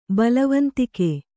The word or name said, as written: ಬಲವಂತಿಕೆ